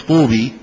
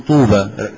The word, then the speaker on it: طوبى: طوبى، انت ما تقولش طوبي، تقول طوبى، رسمتها ايه؟ ياء ونطقتها ايه؟ ألف، والباء عليها ايه؟ فتح.